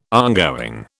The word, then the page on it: ongoing: British English